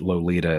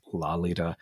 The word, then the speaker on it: Lolita: Can I just say one last quick thing, which is that we talked about that Nabokov insisted that Lolita is a mispronunciation. It should be pronounced Lolita.